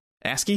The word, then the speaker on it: ASCII: Yeah, the 1980 video game Rogue, which was just an ASCII game. ASCII? – Yep. – Is that how you pronounce that? – Yep.